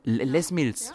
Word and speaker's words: Les Mills: Du bist diplomierte Fitnesstrainerin, Ernährungscoach, Piloxing, Piloxing, Knockout Instructor, Jumping Fitness Instructor, Body Jump Le Mille habe ich das richtig ausgesprochen? Les Mills fast.